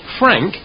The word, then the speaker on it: Frank: Yes, Frank Moorcroft. – Oh, could you spell that, please? – Yes, Frank, F-R-A-N-K, Moorcroft, M-O-O-R-C-R-O-F-T.